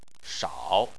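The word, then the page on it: shao: tone 3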